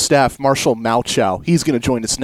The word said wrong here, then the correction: Malchow: Okay, so I've just been told I got Marshall's name wrong. I'm sorry about that. It's Marshall Malchow, like Shane Falco from that football movie back in the day.